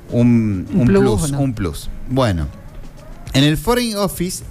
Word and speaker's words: Foreign Office: Sí, Foreign Office.